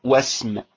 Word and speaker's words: واسم: لكن لو تقدمها واو العطف أقول وسم، وسم، أقول فلان واسمه كذا، لكن لو بدأت بها أقول إي اسمه، وهكذا.